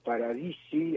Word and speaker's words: Paradisi: Vos sabés que estaba rescatando muy especialmente esta lógica que contaba Lionel Paradisi, así se pronuncia su apellido, ¿no? Sí, es un apellido de origen italiano. Si lo querés pronunciar a la francesa, sería Paradisi. Paradisi. – Ah, Paradisi.